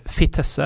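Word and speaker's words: Vitesse: At Fitesse Eller Fitesse er det visst det uttales, ifølge Paul Thomas Clay, som er eh, en av få som bryr seg om denne ligaen i Norge.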